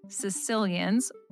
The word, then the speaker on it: caecilians: So I'm coming to you today with a story about Sicilians, which are not people from Sicily. It's very confusing. And I believe this is the correct pronunciation. It's spelled C-A-E-C-I-L-I-A-N-S. So these are legless, worm snake looking amphibians.